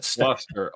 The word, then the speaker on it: Gloucester: Gloucester.